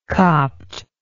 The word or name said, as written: copht